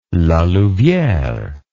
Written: La Louviere

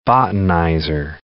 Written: botanizer

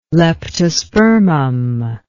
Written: leptospermum

